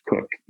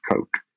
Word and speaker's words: Coke: I have always heard that it is pronounced Cook, even though it is spelled Coke. And I agree with you. Look at C-O-K-E. I think that I should be pronouncing it Coke.